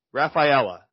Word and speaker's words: Rafaela: Uh, Ceddanne Rafaela, Rafaela, Rafaela, no, Raf, Rafaela is how you pronounce that last name, I believe.